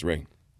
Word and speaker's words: Ra: Was Ray. Sun Ray. As they pronounce it, Americans call it Ra. Okay. But an Egyptian guy told me it's Ray.